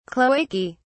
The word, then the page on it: cloacae: British English